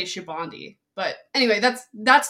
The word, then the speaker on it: Sabaody: It's actually pronounced Shabandi. – That's right. You told me that before. It's okay if you pronounce it that way. You wouldn't know this unless you knew Portuguese, but there's part of the word for that means soap in Sabaody.